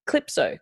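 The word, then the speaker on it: Clypso: This is The Avalanches with Wherever You Go, but it's also featuring Jamie XX and Nina Cherry and Clipso. Clipso? Is that how you pronounce it? Am I saying that right? – I would say so, yeah.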